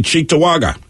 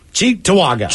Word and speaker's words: Cheektowaga: That would be Cheektowaga. – Cheektowaga. – Cheektowaga. That's how you know you're mostly in Western New York.